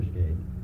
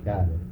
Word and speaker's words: Skade: Yeah, Alfred Matthew Skade. – And how do you pronounce that in English? – Alfred Matthew Skade. – And how do you pronounce it in Danish? – Alfred Matthias Skade.